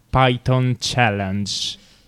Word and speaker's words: Python Challenge: lo Stato americano ha indetto una Python Challenge. Detto giusto? – Bravo.